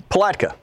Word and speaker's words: Palatka: Palatka.